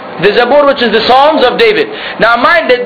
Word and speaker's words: Psalms: Psalms. It's written with a P, but the P is silent.